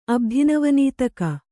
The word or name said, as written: ಅಬ್ಧಿನವನೀತಕ